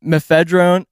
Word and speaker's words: mephedrone: So, mephedrone... Fuck. How'd you pronounce it? – No, you said it right. – Mephedrone? – No, you said it right. – Oh, mephedrone. Yeah.